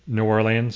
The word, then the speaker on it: New Orleans: How did I pronounce that word? New Orleans? – You did well. – Okay. – You did well. – I pronounced it Americanly, but not natively.